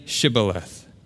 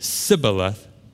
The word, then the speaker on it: shibboleth: They said to him, "Then say Shibboleth." And when he said Sibboleth, for he could not pronounce it right, then they seized him and slaughtered him at the fords of the Jordan.